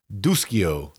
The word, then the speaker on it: Duscio: And this one was by James D-U-S-C-I-O. – Don't know how to say it. – Duschio. – There you go. – Might be a good Italian boy. Yeah. Jim Duschio.